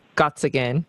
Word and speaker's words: Gottsagen: Zach's on Instagram, and it's just his full name, Zachary Gottsagen. Did I say that right? Yes. – Yeah.